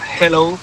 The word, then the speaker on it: hello: Y la segunda, dile a Elena que su dicción en castellano es la más perfecta que conozco, porque cuando diga hello, que no pronuncie la J, que no es hello, que es ya. Hello, que la aspire la J y no pronuncie la J potente castellana.